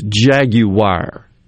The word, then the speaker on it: Jaguar: I think he's saying there, that according to the BBC, it's Jaguar. – Jaguar, yeah. – Is the way it's pronounced.